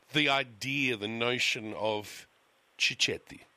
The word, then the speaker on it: cicchetti: Am I saying it right? – You could... You know, you're not. – No. So, yeah, that's it. I can tell. I just hang about. I'm doing it for myself. Ow! Okay, I'll slap myself. Say it properly for me. – Cicchetti. – Cicchetti. Okay. – Yeah. – Better? – Perfect.